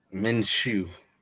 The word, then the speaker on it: Minshew: My best of the week, obviously, I'm biased when I say this, but Tom Brady or Gardner Minshew. I hope I'm pronouncing his name right. Gardner Minshew? – Minshew.